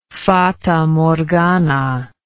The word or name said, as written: fatamorgana